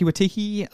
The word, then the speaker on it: Waititi: But anyway, Hunt for the Wood People's Taika Waititi.